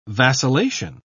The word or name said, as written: vacillation